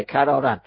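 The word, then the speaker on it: کراراً: کرارن، بله بله، کرارن. ببینید، درست تلفظ کردم.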